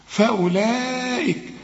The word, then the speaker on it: فأولئك: إذا لقيت أي حرف في القرآن عليه الخمسة ده اوعى تنطقه، قل لي فأولئك وكأنه غير موجود، فأولئك وده مد واجب، فأولئك هم الظالمون، شوف أعلى درجات التفخيم وأقل درجات الترقيق، الظالي الظالمون.